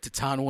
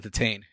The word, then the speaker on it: Titane: Nothing annoys me then when people talk about Titane and they call it the tane.